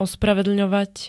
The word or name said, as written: ospravedlňovať